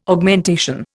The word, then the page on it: Augmentation: Indian